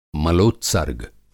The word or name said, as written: मलोत्सर्ग